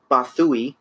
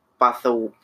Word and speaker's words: Bothawui: Commissioned to protect the strategic world of Bathui. I think that's how you say that. – Bless you. – Bathui. – How do you spell it? – B-O-T-H-A-W-U-I.